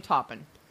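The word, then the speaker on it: Toppan: Her name is Jane, and I don't know if her last name is spelled, pronounced Toppin or Topin. It's T-O-P-P-A-N, so I'm saying Toppin.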